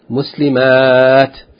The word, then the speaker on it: muslimat: In pronunciation, the only difference between the singular and the sound feminine plural is the shortness and length of the A. Muslima, muslimat, and the ending with ta marbuta as opposed to ending with ta maftuha.